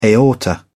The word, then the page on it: aorta: British English